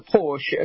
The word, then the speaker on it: Porsche: We say Porsche, but it's not Porsche. What's the correct way to pronounce it's, P-O-R-S-C-H-E? – Yeah, Porsche.